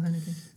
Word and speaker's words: Haneke: Michael Haneke. Sim, do Michael Haneke. – Exatamente. Acho que pronunciaste muito bem.